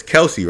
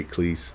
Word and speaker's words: Kelce: It's Kelce, right? I keep on saying his name wrong. I say Cleese. I don't know why I say his name like that.